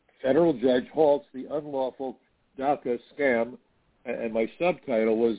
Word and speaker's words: DACA: Of course, DACA rhymes with CACA, just saying.